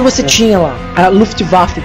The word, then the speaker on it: Luftwaffe: Então eu fico falando, é a Luftwaffe.